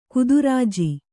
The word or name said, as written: ಗೋತ್ರಜ